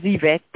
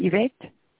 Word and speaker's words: Yvette: Yes, this is Yvette, pharmacist.